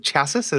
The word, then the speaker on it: chassis: Like, his chasis allows him to stretch. – I need to talk to you about the word chasis. – Is it chassis? Am I mispronouncing it? – Chassis. I'm sorry.